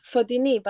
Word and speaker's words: Fotini: It's Fatini, but Fatini will do. Fatini.